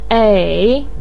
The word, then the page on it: ei3: pinyin